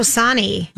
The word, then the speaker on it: Sonny: Getting to know Sonny.